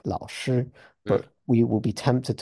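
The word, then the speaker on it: lao (老师): so that we'll pronounce the first character there like lao with a rise, and that lao shi, and then they'll say no, it's lao, and they'll say it again with the rise, and then you'll It was bizarre the number of the conversations I had like that.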